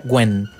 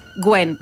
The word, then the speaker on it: Nguyen: O en otro momento en el que Nguyen, ¿cómo se dice sueco? – Acá nos aporta Inés por Telegram que se pronuncia Gwen. – Gwen, muchas gracias.